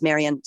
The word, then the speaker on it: Marion: And my first name is Marion, spelled with an O at the end, M-A-R-I-O-N.